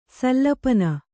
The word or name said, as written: ಸಲ್ಲಪನ